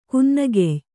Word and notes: ಕುನ್ನಗೆಯ್: kannada

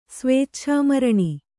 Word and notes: ಸ್ವೇಚ್ಛಾಮರಣ: kannada

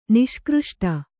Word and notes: ನಿಷ್ಕøಷ್ಟ: niṣkřṣṭa

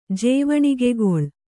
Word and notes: ಜೇವಣಿಗೆಗೊಳ್: Kannada

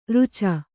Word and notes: ರಚ್ಚೆ: racce

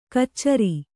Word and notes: ಕಚ್ಚರಿ: kannada

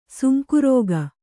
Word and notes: ಸೊಂಕುರೋಗ: sonku rōga

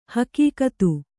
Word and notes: ಹೆಗ್ಗತ್ತು: heggattu

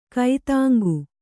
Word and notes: ಕೈತಾಂಗು: kai tāŋgu

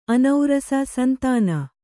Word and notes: ಅನೌರಸ: anaurasa

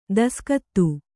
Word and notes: ದೇಸಿಗಾತಿ: dēsigāti

